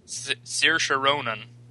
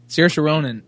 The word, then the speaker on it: Saoirse Ronan: Saoirse Ronan. – Saoirse Ronan.